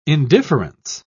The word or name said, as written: indifference